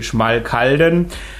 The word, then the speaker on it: Schmalkalden: It's a town in a territory in the center of Germany, which is called Thuringia, and the German pronunciation would be Schmalkalden.